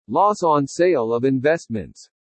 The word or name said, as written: LOSS ON SALE OF INVESTMENTS